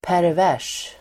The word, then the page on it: pervers: pärv'är_s: